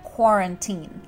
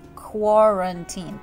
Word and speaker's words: quarantine: Кстати, про произношение тоже здесь заострим внимание. Quarantine. Quarantine произносится.